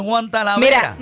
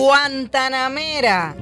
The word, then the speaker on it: Guantanamera: Mira, es cubana y te está diciendo cómo se dice. – No es Guantanamera. – No es Juan Talavera. – No es Juan Talavera. No es Aguántame a Mela. No, nada que ver. Guantanamera.